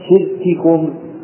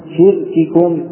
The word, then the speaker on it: شرككم: وراء شدة بكاف وبتاء كشرككم وتتوفى في فتنة، أي وراع الشدة في الكاف والتاء، ثم ذكر مثالا الكاف في كلمة شرككم شرككم، فالأولى مكسورة الكاف الأولى مكسورة والثانية مضمومة شرككم، وكلمة تتوسى أن الإنسان قد يصدق وينطق بما تاء واحدة ولكن يجب توضيحهما يجب توضيحهما، تقول تتوسى تتوسى.